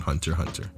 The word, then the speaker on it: Hunter x Hunter: When you watch anime, you don't pronounce the X. You just call it Hunter x Hunter.